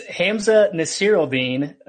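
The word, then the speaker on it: Hamsah Nasirildeen: That is Hamza Nasiruddin. – That's actually easy. – It's not too bad if you say it phonetically, but really smooth transition there from Samuel to Nasiruddin.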